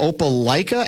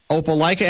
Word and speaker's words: Opelika: Is it Opelika, Alabama? – You got it. – Opelika, Alabama, and the Auburn area.